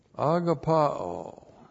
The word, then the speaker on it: agapao: This is the Apostle John's favorite verb, agapao, A-G-A-P-A long O, uses all throughout 1 John, 2 John, 3 John, agapao.